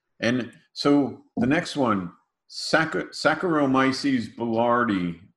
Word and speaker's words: Saccharomyces boulardii: Yeah, Saccharomyces boulardii.